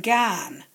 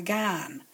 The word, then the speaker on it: gan: Some people spell it L-A-R-L, but you can spell it L-A-A-L, lal, you see. You get that sort of thing. And gan, if you're going somewhere, gan. It's a long vowels.